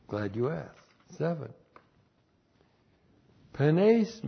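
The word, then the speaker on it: Penes (πένης): Penes. I guess I didn't put it up there. Penes. P-E-N, long E-S. Penes.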